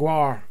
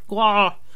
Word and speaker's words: Gwar: Gwar. – Gwar.